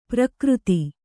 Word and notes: ಪ್ರಾಕೃತ: Kannada

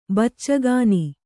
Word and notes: ಬಚ್ಚಗಾನಿ: baccagāni

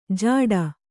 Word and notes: ಜಾಡ: Kannada

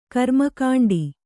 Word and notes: ಕರ್ಮಕಾಂಡ: karmakāṇḍa

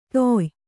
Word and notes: ತೊಯ್: Kannada

